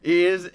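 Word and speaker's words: Ys: East 8.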